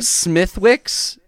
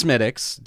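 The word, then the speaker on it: Smithwick's: Smithwix, but it's Smittix. Don't make that mistake.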